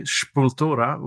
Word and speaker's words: Sepultura: I grew up with a band from Brazil, Sepultura, of course, the big, like, Sepultura, or as my friend Andre Lorja would say, Sepultura, which is how they pronounce it in Madeira.